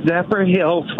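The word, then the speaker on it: Zephyrhills: Zephyrilla, Florida. Am I saying that right? – No, Zephyr Hills, Florida. – Oh, Zephyr Hills, two words.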